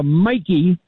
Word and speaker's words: Miki: We're broadcasting on a unit called the Mikey, like Mikey Boyle, M-I-K-I is the way it's spelled.